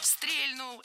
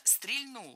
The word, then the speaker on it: стрельнул: Стрельнул – это неверно. Правильно, стрельнул. Хотя в разговорной речи вариант с ударением на первом слоге я слышу часто. Это, можно сказать, такой сленг. И даже грамотные люди так иногда говорят, привнося в это слово иронию. Но литературная норма – стрельнул.